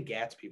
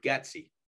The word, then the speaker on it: Getsy: I always say Gatsby whenever I say his name because of the book Great Gatsby, read that in English class back in the day. Luke Getsy. That was Davante Adams' favorite coach,